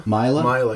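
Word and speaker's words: Maila: So Mala Nurmi, which is how I say it. – Wait, how do you say it? – Mila. – I like Mila. Let's go with Mila.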